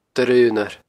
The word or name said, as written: Doire Aonar